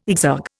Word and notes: exergue: Indian

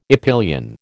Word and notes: epyllion: American English

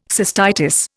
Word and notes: cystitis: Indian

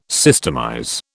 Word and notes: systemize: British English

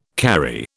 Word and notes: karri: British English

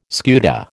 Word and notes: scuta: American English

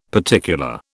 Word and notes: particular: British English

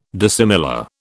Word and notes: dissimilar: British English

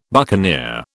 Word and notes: buccaneer: British English

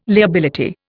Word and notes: liability: Indian

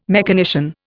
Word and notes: mechanician: Indian

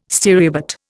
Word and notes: stereobate: Indian

